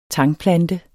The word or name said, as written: tangplante